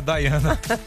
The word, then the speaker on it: Diana: É igual a princesa Diana. – Isso, exatamente. – Que é a Daiana.